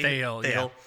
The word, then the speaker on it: Thayil: Thayil.